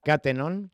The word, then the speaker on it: Catenon: Entonces eso genera este tipo de problemas, que en función de dónde pongamos el acento, se pronuncia de una manera u otra. Ya no se me olvida, Mariana, Catenon.